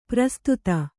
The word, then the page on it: ಪರಿಸ್ಥಿತಿ: pari sthiti